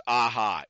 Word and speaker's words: AHA: It's pronounced AHA, A-H-A.